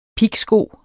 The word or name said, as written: pigsko